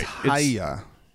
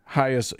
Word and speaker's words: Hagia: I think it's Hagia. – Hagia. Yes, correct.